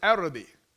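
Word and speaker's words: Arabi (عربي): The actual word is Arabi. And in Arabic, that's how you say it even today.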